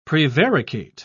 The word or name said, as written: prevaricate